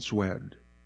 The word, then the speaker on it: Szwed: Carol Robertson Zwed. That's spelled S-Z-W-E-D. Carol Robertson Zwed, 75, of Palmer, passed away on April 19th.